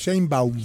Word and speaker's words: Sheinbaum: si el Estado de México y Claudia Sheinbaum ¿Claudia qué? Seimano, ¿cómo es? Seimbaum. Seimbaum.